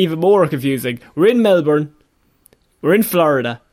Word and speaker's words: Melbourne: The other one, is pronounced Melbourne, I'll have you know. – Of course, there's no R in it, everyone knows that.